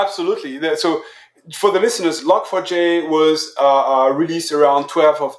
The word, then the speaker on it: Log4j: And also, I poked around your website earlier, and I saw you have how do you pronounce this? Is it LogForge or Log4j? – Yeah, Log4j, the tracker.